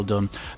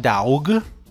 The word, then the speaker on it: dough: D-O-U-G-H. No, it is not daug. It is do. Think of no, but with a D.